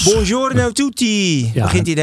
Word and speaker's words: Buongiorno: Buongiorno, Ivo. Zeg ik het goed? – Bijna. Buongiorno. Je buongiorno. Moet de rollende R.